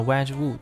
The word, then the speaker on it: Wedgwood: 啊，这个也是我送礼当中的一个必备，叫 Wedgwood，它是一个英国的牌子，专门做陶器那个那个起家的。 – 嗯，呃一我呃一七三几年吧就已经创立了。嗯啊，创始人在英国创立的这个牌子 Wedgwood，它。